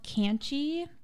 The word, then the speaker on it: Kannchy: I have another one on here called Canchy, and it's spelled K-A-N-N-C-H-Y.